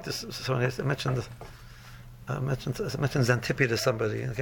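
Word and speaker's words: Xanthippe: But she was such a shrew that there's a word in the dictionary, uppercase X, spelled with X, but it's pronounced Xantippe.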